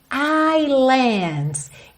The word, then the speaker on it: islands: Essa palavra ilha, aqui no caso está no plural, ilhas, islands em inglês, esse S é mudo. Isso não é comum em inglês, tá? Não é uma coisa corrente, mas acontece nessa palavra.